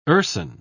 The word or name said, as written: urson